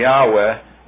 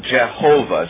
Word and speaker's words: Yahweh: And let's say if we can make a shift over, we say Yahweh rather than Jehovah. Jehovah is, in all likelihood, not the correct pronunciation for God's name.